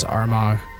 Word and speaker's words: Armagh: Armagh, which is spelled A-R-M-A-G-H.